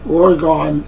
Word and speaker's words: Oregon: And just remember that Oregon is in fact 2 syllables, and Long Island has a hard G sound.